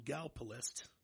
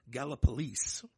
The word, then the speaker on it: Gallipolis: If you read, this town is called Galpalist. That's not how it's pronounced. It's Gallipolis. Mm-hmm.